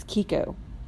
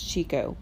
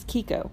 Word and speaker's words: Kiko: It's Kiko. It looks like it's Chico, but it's Kiko.